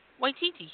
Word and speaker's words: Waititi: Waititi, yeah.